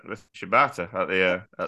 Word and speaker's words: Shibata: Uh, Yuta's called out Sh- uh, Shibita. I can't say his name. Shibata.